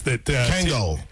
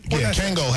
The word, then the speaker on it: Kangol: Kangor hats. – Kangol. Yeah, he's in. – Did I say it wrong? – Kangol hats. You said Kangor.